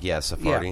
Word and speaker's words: Sephardi: And I can't pronounce that word, is Sephardi? – Sephardi. Yeah.